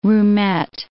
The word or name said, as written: roomette